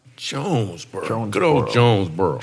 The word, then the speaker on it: Jonesboro: Yeah, Jonesboro.